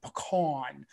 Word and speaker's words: pecan: And then you say the typical New Orleans way, pecan, A-W-N, pecan.